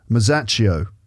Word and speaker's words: Masaccio: Masaccio.